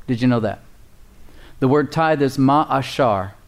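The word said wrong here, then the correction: Ma'aser (מעשר): Ma'ashar, it's spelled M-A-A-S-E-R. Ma'aser, excuse me, is how you pronounce it.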